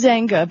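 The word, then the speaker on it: Zynga: It is Zenga, because I spoke on a panel with the CEO once.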